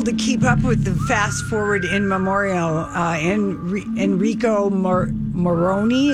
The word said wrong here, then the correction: Ennio Morricone: is that how you say his name? – I believe it's Ennio Morricone.